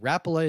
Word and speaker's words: Rapala: Rapala is the correct way to pronounce it according to this game.